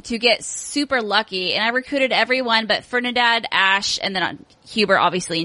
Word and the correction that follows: Ferdinand: Ferdinand, yeah.